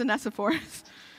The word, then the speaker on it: Onesiphorus: Google doesn't get that one right. It's Onesiphorus.